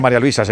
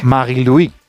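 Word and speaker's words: Marie-Louise: Claro, bueno, si es que no se dice María Luisa, se dice en su... – Sí. Magui-Louis.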